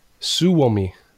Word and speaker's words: Suomi: Or Swomi. am I saying that right? Suomi? – Yeah. – Suomi? – Suomi. – Suomi. Yeah. That is the real word for Finnish, by the way, RC and listeners.